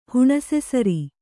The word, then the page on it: ಹುಣಸೆಸಾರು: huṇase sāru